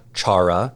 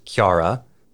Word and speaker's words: Kiara: Her name is not Chara. Her name is Kiara. – So is it Kia? Kiara? – Yeah, it's oh. – It's like the first two syllables of the Japanese way of saying caramel.